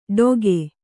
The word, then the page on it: ಡೊಗೆ: Kannada